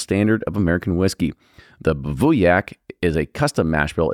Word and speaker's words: Bivouac: So Bivouac, and that's exactly how it's pronounced is what it says on here,